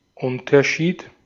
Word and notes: Unterschied: IPA: [ˈʔʊntʰɐˌʃiːt]; IPA: /ˈʊn.tɐ.ˌʃiːt/

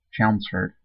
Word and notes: Chelmsford: /ˈt͡ʃɛlmzfəd/

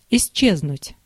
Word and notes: disparaître: [dis.pa.ʁɛːtʁ]; /dis.pa.ʁɛtʁ/

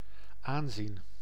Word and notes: aanzien: /ˈaːnˌzin/

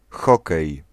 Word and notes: hokej: [ˈxɔkɛj]

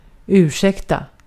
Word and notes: ursäkta: [ʉː rˈsɛkta]

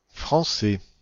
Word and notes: français: /fʁɑ̃.sɛ/

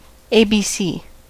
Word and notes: aakkoset: /ˈɑːkːoset/

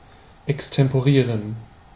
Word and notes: extemporieren: /ɛkstɛmpoˈʁiːʁən/; [ʔɛkstɛmpoˈʁiːɐ̯n]

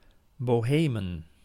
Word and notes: Czechy: [ˈt͡ʂɛxɨ]

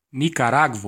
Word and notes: Nicaragua: American English; /ˌnɪk.ə.ˈɹɑ.ɡwə/

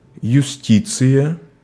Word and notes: justice: France; [la ʒys.tis]

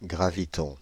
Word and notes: graviton: Île-de-France; /ɡʁa.vi.tɔ̃/; [ɡʁa.vi.tɔ̃]

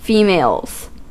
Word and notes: females: /ˈfiː.meɪlz/

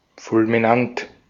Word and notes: hatalmas: /ˈhɒtɒlmɒʃ/

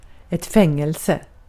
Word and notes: prison: American English; [ˈprɪzən]; English

